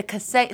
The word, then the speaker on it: Kaseya: The Kaseya. So that's how you say it.